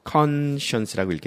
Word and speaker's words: conscience: 그러니까 여기 나온 conscience라고 읽게 되지. 콘에 강세가 있으니까. 원래 강세가 science 있으면 conscience라고 읽을 거라고. 그런데 강세가 맨 앞에 있으면 나머지는 다 약하게 어를 읽게 돼 있어. 그래서 이건 conscience라고 읽는데 conscience라는 것은 누구나 다 알 수 있는 것, 양심.